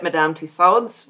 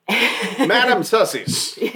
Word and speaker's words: Madame Tussauds: Mueller had a full-length portrait up at Madame Tussaud's within a few days of the execution. I never pronounced that the same way twice. – Madame Sussies.